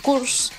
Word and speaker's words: course: Y se ve que pronuncié mal la palabra cures. – Ajá. – Porque no, un curso no se dice cures, se dice course.